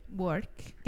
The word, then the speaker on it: Buarque: Buarque.